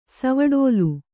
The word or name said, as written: ಸವಡೋಲು